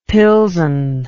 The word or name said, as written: pilsen